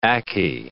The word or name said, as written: ackee